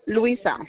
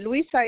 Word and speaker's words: Luisa: Okay, Gutierrez, Luisa. – Are you able to spell that for me? – Of course I do, madam. Luisa is L-U-I-S-A.